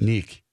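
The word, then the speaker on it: Niek: He's how's how's N I E K pronounced? Uh, pronounced Nick.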